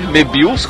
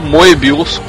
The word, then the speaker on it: Moebius: Segundo Pedro, é ou Mebius, como o próprio dizia, ou Moebius, como é uma pronúncia bastante comum, então, na França.